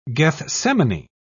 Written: gethsemane

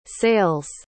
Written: sale's

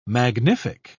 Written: magnific

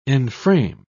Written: enframe

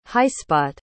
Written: highspot

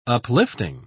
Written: uplifting

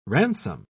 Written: ransom

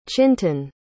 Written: chintan

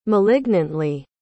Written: malignantly

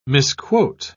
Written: misquote

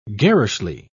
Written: garishly

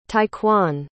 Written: tyquan

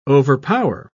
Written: overpower

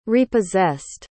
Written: repossessed

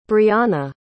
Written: brionna

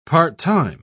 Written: part-time

